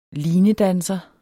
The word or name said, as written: linedanser